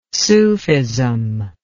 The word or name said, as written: sufism